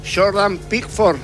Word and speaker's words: Jordan Pickford: Jordan Pickford será el arquero.